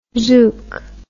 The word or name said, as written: zuke